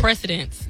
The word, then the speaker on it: precedence: I have a president, mm-hmm. Presidential case. – Yeah. – Did I pronounce it right? – Precedence. Yes.